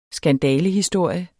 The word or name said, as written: skandalehistorie